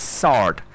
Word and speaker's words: sword: S-double-O-R-D, it's not sword, it's sword.